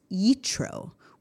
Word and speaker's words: Yitro: In Hebrew, Jethro is pronounced Yitro, which comes from a word that means more.